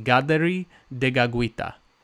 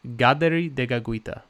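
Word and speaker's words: Kateri Tekakwitha: But she was a Mohawk Indian, and her name actually in Mohawk is pronounced Gaderi Degagwita. Gaderi Degagwita, which I think is really beautiful.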